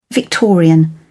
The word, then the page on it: victorian: British English